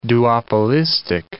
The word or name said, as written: duopolistic